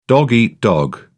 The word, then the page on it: dog eat dog: British English